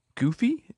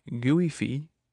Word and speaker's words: Guifi: In Catalonia, they have a network called Gufi. It's spelled G-U-I-F-I. Gufi. Gufi.